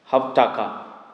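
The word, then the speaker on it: havtacha: In Hebrew, the word promise is called Havtaka.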